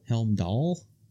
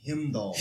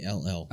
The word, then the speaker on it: Heimdall: Helmdall? – Heimdall.